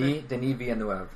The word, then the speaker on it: Denis Villeneuve: Denis Villeneuve. – Okay, thank you. The pronunciation part. – I think it's Canadian French, I believe.